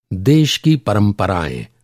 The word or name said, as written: परंपरा